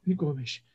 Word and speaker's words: Gomes: Gomes. – Uh, so Doucouré weird Gomes. you're saying that. – Yeah. – So weird. – I believe the Portuguese pronunciation is with a sh at the end.